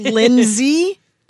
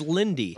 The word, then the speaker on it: Lynndie: It's Lindsay? – I think it's Lindsay, but it's spelled really weird. – No, it's Lindy. – Or Lindy, yeah. – Oh, Lindy. – L-Y-N-N-D-I-E.